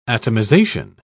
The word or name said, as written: atomization